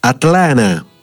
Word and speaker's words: Atlanta: Però non pensiate di arrivare ad Atlanta e chiamarla così, perché Atlanta si pronuncia Atlana, così come ho imparato io andando proprio in quella città.